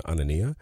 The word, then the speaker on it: Anania: Also, I have a Facebook artist page with just the last name, Anania.